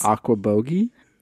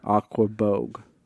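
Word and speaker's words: Aquebogue: Aquabogue. – Close. – Aquabogie? – No. Other direction. – Aqua? – No. – Aqua? – Uh-huh. – Aquabogue. – Bog.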